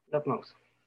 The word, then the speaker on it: Lovemouse: Entonces, quiero también que tú me corrijas si lo estoy pronunciando mal, es Lovemouse. – Lovemouse. – Ah, perfecto, ya vieron que no lo mastico tan feo, dice.